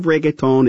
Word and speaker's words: reggaeton: We'll hear from Carlos Vives a little later, but right now, the queen of reggaeton is up next.